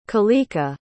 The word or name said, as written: kalika